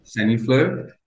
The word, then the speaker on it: Saniflo: Do I pronounce it correctly? Is it Saniflo? – Saniflo.